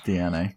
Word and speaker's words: DeNA: See if it does actually come to fruition with DNA. – Mm-hmm. – D-E-N-A, is that right? – It's D-E-N-A, but yeah, it's pronounced DNA.